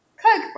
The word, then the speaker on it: Koch: Oh, yeah, the Koch brothers. – Koch brothers. Sorry, I have been saying it wrong. Okay, the Koch brothers.